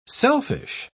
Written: selfish

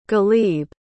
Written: ghalib

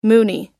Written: moonie

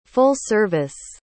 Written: full-service

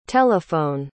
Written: tel

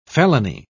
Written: felony

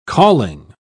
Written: calling

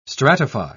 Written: stratify

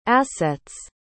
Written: asset's

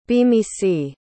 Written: bimisi

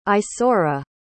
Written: isaura

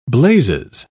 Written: blazes

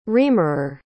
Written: reamerer

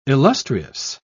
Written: illustrious

